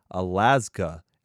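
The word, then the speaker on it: Alazka: Next up, we have Alaska, and that is pronounced that way because there's a Z. So it's A-L-A-Z-K-A, and they have their new album, Phoenix, on Sharp Tone Records.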